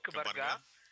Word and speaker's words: Кабарга: Ударение на последний слог. Кабарга.